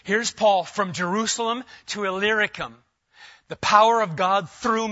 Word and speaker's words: Illyricum: So that from Jerusalem and all the way around to, if you want to know how to pronounce that word, just look for the little word Lyric inside Illyricum. It makes it a lot easier. I failed a lot of times trying to pronounce that word until you see that little word Lyric in there, then you're set.